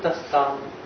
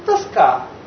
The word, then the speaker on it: Тоска: Тоска по любимой женщине. А в Европе просто ударение. Тоска. Тоска.